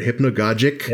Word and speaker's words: hypnagogic: Shirley, your topic here is, I'm not sure how to pronounce this word, hypnagogic, yes.